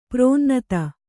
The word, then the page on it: ಪ್ರಾಣನಾಥ: prāṇa nātha